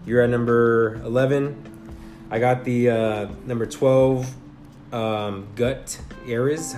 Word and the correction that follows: Gutierrez: How you say it? Gut Ares? Oh, Gutierrez Weston.